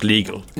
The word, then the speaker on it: LIGL: So with the law firm, so it's the pronounced legal. It's written something in the different way, but it's pronounced legal.